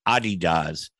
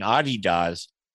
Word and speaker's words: Adidas: He's worked with Callaway and TaylorMade and Adidas, or I should say it right, Adidas. Isn't that correct? – That's correct. That's how it's pronounced in Germany.